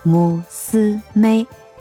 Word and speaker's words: 娘: 女儿呢，在日语当中是一个娘，姑娘的娘，还有就是母亲那个娘一样的一个汉字，但它的发音呢叫母斯妹，有趣吧？